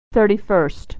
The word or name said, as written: 31st